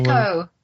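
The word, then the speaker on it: Nekko: I didn't use it a lot, but there were moments where when I was riding, God, was it Neku? The little Neko. – Echo, thank you. I keep saying it wrong.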